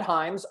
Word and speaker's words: Hyams: Me, uh, Chad Hyams, I tell people my last name is spelled H Yams. I don't eat them, I don't like them. It's just the easy way to remember how to spell my name.